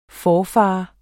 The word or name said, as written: forfader